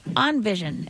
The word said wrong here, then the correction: InVision: is that correct pronunciation? – Oh, we usually say InVision. – InVision, On Vision, InVision, okay, E-N, yeah.